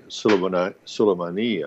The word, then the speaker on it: Soleimani: General Qasem Soleimani, who has taken Soleimani, I should say, there's a long E at the end of his name,